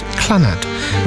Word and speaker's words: Clannad: Three of my older siblings were members of the band Clannad, which is spelt C-L-A-N-N-A-D, just in case I didn't pronounce it correctly.